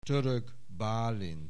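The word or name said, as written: Török Bálint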